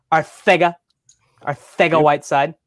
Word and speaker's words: Arcega-Whiteside: Arcega-Whiteside. We have Quez Watkins. – Say it right. – Arcega-Whiteside.